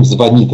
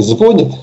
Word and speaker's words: звонит: Но, скажем, мы до сих пор, образованные люди, во всяком случае, говорим «звонит», а не «звонит», хотя большинство глаголов такого типа, да, «звоню-звонит», уже сдвинули ударение в сторону «звоню-звонит».